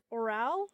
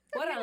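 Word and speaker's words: órale: So, do you know how to say this word? – Oral? – So wrong. – Orale. You're cute, but you're an idiot.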